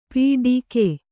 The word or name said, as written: ಪಿಡಿಕೆ